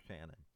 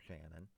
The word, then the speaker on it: Shannon: Whether it's going on Ramblings, the Ramblings of Paul Shannon, THE Ramblings of Paul Shannon, or, and my last name is spelled S-H-A-N-N-O-N.